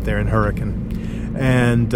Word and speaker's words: Hurricane: Now, it's spelled Hurricane, but it's pronounced Hurricane. That's how the locals pronounce that.